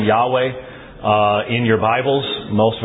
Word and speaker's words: Yahweh: In fact, the divine name in the Old Testament, Yod-Heh-Wal-Heh, Yahweh, only has two syllables. But Jehovah has three.